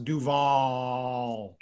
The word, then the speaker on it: Duval: And then the other one you mentioned was Duval, which is correctly pronounced Duval. You gotta drag it out for at least a minute and a half, which is where Jacksonville is.